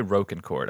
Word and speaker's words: Rocancourt: Would you call it Rocancourt or Rocancourt? The documentary I watched said Rocancourt. – I would say Rocancourt. I would say long O there.